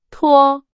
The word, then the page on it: 脱: Chinese